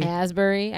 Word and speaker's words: Asbury: Ashbury? – Asbury. – I never say his name. – Ashbury. – Right. I put that H in it.